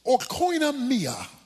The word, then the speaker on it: oikonomia: But the Greek word is pronounced oikonomia. It's translated stewardship, depending on the version of the Bible you have. It's translated administration. Other versions translated dispensation. The word oikonomia referred to the management of a household, how a household is run, how you run your house.